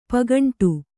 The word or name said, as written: ಪೌಗಂಡ